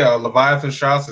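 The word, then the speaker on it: Leviathan Straus: All right, and follow me, Leviathan Strauss. It's spelled exactly like the serpent and exactly like the pants, but minus one S.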